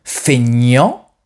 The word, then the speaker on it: feignant: Écoutez bien la différence de prononciation entre ces deux adjectifs. Feignant, fainéant. Feignant, fainéant.